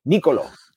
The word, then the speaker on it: Nicolo: And I would say, Nicolo. And they were like, no, that's not your name. And so they had to teach me how to say my name, Nicolo.